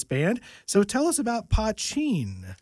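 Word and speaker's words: Poitín: And you've got a band called Pochin, though it's spelled P-O-I-T-O-N, which essentially is Irish moonshine.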